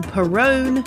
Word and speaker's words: Perrone: I'm your host, Jane Perrone. It rhymes with Stallone. But if you can roll your R's, please feel free to zhuzh it up a bit.